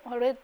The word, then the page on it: オレ: o[re; 茂倉